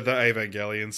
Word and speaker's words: Evangelion: Yeah, I would say the Evangelion series. – Evangelion. – Yeah. And I appreciate your pronunciation there. Evangelion. Are kind of the two ones, but it's that hard G.